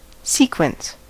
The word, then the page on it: sequence: American English; /ˈsikwəns/; /ˈsikwɛns/